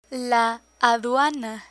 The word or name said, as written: aduana